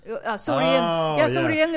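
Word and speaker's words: sầu riêng: Sorien is how you say in Vietnamese.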